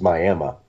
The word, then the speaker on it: Miami: It's easy to mispronounce stuff from here because, I mean, there's a place called Miami about 80 miles north of here that's pronounced Miami.